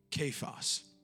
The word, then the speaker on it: Cephas: And when Jesus beheld him, Jesus said, "You are Simon, the son of Jonah, but thou shalt be called," the Aramaic, it's pronounced Cephas, which is by interpretation a stone.